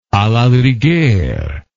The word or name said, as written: a la rigueur